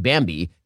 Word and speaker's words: Bambee: See how much Bambi can take off your plate.